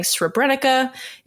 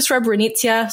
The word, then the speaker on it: Srebrenica: I've been saying Srebrenica. It's actually Srebrenica, so I will pronounce it correctly from here on out, and I apologize for the past 10 minutes.